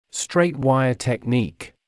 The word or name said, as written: straight wire technique